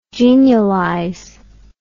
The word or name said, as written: genialize